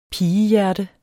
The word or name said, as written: pigehjerte